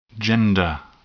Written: gender

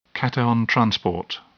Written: cation transport

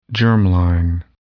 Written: germline

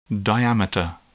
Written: diameter

